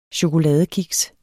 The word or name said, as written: chokoladekiks